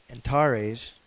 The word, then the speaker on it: Antares: And then the largest there we have is Antares. It shows a long E.